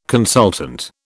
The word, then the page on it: consultant: British English